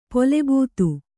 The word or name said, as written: ಪುಲುಬೂತು